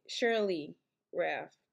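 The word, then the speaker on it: Sheryl Lee Ralph: Cheryl Lee Ralph.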